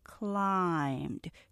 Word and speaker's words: climbed: climbed 천천히 하나로 발음하셔야 되죠.